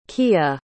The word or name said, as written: quilla